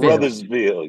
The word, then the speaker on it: Caruthersville: It's Caruthersville. – Caruthersville, yeah, Caruthersville. – So can you break down what's the distance between Caruthersville and St. Louis and the differences? – So Caruthersville is like a super small town.